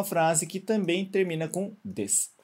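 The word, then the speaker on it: Des (です): A gente escreve D-E-S-U, né? Mas a gente não vai pronunciar esse U, lembra? A gente não vai falar desu, né? A gente não fala desu nem desu. Né? A gente não pronuncia o U. Então, na prática, é como está aqui do lado, a gente só pronuncia até o S.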